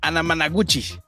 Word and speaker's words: Anamanaguchi: que hijo Nunca la puedo pronunciar, güey, tú dilo. Ana, Anamana, Anamanaguchi. Eh, sí, lo logré.